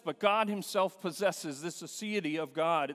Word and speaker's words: aseity: Divine aseity, that's A-S-E-I-T-Y, because I knew you wanted to spell it. And so A-S-E-I-T-Y,